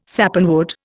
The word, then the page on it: Sappanwood: Indian